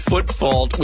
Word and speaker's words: foot fault: A foot call, a foot foot, a fault call. – Yeah, anyone that tries to correct their own mispronunciation of foot fault with a foot foot.